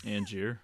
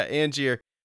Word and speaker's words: Angier: Angier. – Yeah, Angier.